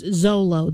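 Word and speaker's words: Xolo: Yeah, but apparently it's pronounced Zolo.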